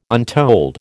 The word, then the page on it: untold: American English